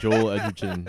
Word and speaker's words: Joel Edgerton: You just butchered that. – Joel Edgerton. Sorry. – You called him Joel. – Joel. I do that a lot.